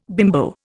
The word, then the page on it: bimbo: Indian